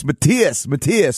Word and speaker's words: Matthias: I grew up in Canada, in Winnipeg, Canada, and of course, not having any idea how to pronounce German names, my whole life I always thought your name was Matthias Jabs. And then the first time I went to Germany, everyone told me, no, it's Matthias.